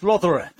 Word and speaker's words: Rothera: Is it Rothera? – Yeah, it's pronounced Rothera. That's right.